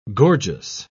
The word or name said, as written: gorgeous